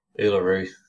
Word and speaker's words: Uluru: Uluru.